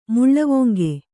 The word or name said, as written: ಮುಳ್ಳುವೊಂಗೆ